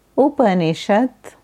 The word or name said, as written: Upanishad